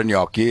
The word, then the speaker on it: gnocchi: That was lobster gnocchi. Mm. Am I saying that right? You're Italian. – Lobster gnocchi, yeah.